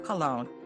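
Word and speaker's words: Colón: He adopted a Taino adolescent, calling him Juan Diego Colon, the correct pronunciation of Columbus.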